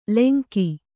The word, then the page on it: ಲೆಂಗಿ: lengi